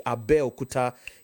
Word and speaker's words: Abeokuta: It's like going to Abeokuta and somebody says, now Abeokuta is a, Abeokuta, let me pronounce it correctly, Abeokuta is a city in the western region of Nigeria in a state called Ogun State.